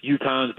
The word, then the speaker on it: Utahns: Yes, Utahns, but if you're like me from New York, you add some W in there, Utahns.